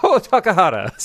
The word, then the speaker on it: Otakara: I will just tell you that that is wrong. – Okay, it sounds Irish the way you say it. – Otakara.